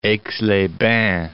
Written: Aix-les-Bains

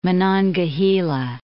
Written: Monongahela

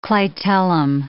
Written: clitellum

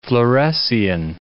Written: fluorescein

